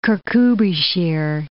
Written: Kirkcudbrightshire

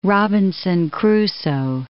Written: Robinson Crusoe

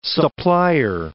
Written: supplier

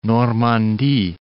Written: Normandie

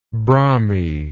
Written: brahmi

